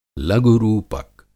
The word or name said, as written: लघु-रूपक